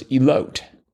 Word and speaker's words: elote: Grilled corn as a Mexican street food was elote. – Elote. – Or that's how you pronounce it? – Mm, I believe so.